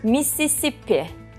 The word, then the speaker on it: Mississippi: Mississippi. – Evet. – Doğru mu telaffuz ettim? – Evet.